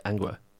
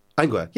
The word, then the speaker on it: Angua: There's Angua. Would you say it like that? – I say like anger, but with a U. Like Angua. – Angua, yeah.